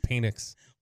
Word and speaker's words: Penix: It's pronounced Penix.